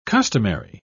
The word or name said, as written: customary